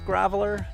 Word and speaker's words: Graveler: I believe it's pronounced Graveler, G-R-A-V-L-E-R.